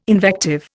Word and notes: invective: Indian

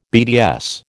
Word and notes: BDS: American English

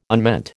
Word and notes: unmeant: American English

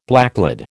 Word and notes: blacklead: American English

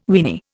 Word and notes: weeny: Indian

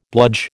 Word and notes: bludge: American English

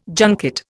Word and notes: junket: Indian

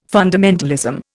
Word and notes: fundamentalism: Indian

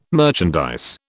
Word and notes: merchandise: British English

